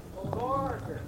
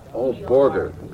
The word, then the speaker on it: Borger: Barger. – Well, anyway, this is the most mispronounced city in the country, folks. This is Barger, Texas? – Borger. – How do you spell it there? – Borger. – B-O-R-G-E.